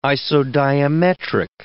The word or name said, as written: isodiametric